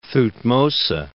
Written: Thutmose

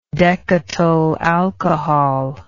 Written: decatyl alcohol

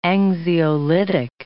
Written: anxiolytic

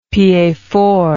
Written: piedfort

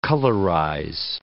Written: colorize

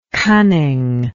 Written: cunningness

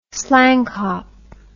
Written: slangkop

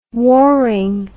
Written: warring

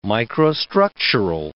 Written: microstructural

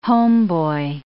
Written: homeboy

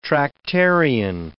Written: Tractarian

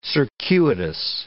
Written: circuitous

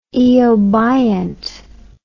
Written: eobiont

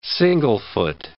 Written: single-foot

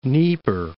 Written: Dnieper

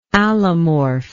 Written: allomorph

